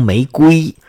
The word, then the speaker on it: 玫瑰: 我知道啊，这玫瑰啊应该读成玫瑰，但是那是书面语，咱老百姓没有说，哎，我要送一朵玫瑰花给你，玫瑰就指玫瑰，我就读玫瑰了，好吧？